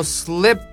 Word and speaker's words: slip: この slip と mind、この単語、この 2つの単語にリズムを置きましょう。ね、両方ともスペル上こうね、 I って書いてあるけど、スペルと発音って対応してないんだよね。必ずしも対応してるわけじゃないんだよね。というわけで、最初の slip は口を横に引かないです。